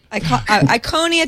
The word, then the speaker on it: Iconia: Acer's Android tablet, the Iconia. God, I can't say that right. I said that wrong earlier. I called it the Iconic, which I think would kind of be cool.